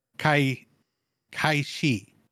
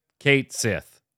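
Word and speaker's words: Cait Sith: Square Enix has decided, according to Final Fantasy VII Rebirth, that it is pronounced Kate Sith.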